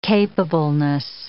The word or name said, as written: capableness